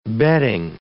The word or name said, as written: bedding